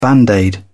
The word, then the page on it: band aid: British English